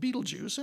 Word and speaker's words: Betelgeuse: and it's commonly pronounced Betelgeuse, and that's okay.